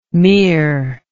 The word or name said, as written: meer